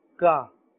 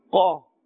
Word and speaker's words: qaf: This letter is pronounced with a thicker sound, a fuller sound. Do not say ka. If you say ka, then we will be mixing it with the next letter, which is the kaf.